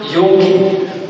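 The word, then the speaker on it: yachid: But it's interesting to know that when it talks about your only son Isaac, the Hebrew word for only here is spelled Y-A-C-H-I-D. Y-A-C-H-I-D. Okay, let me put it up there. It's weird. It's pronounced Yorki. Yorki. So this is Yorki. Your only son, Yorki. It's not Yahweh. It's Yorki. That's how it's pronounced.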